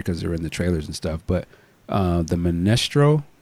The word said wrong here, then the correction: Mysterio: is that how you say it? Mestro? – Mysterio, excuse me.